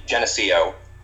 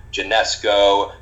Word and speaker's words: Geneseo: It's SUNY Geneseo, and Geneseo actually comes from a Seneca word.